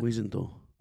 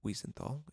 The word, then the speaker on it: Wiesenthal: You didn't say his name right either. – I did, yeah. – What was it? – Wiesenthal. – Wiesenthal, exactly. Searched for Wallenberg and collected several testimonies.